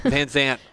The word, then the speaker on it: Van Zandt: Great. Van Zandt? – Sounds right. Van Zandt? – Yes. – Yeah. – Oh, that sounds better. – The D is silent. – The D is silent. – Van Zandt.